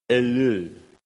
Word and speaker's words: Ellul: Let me finish with a quote, sort of an extended quote from a guy called Jacques Ellul. E-L-L-U-L. That is exactly how you pronounce his name.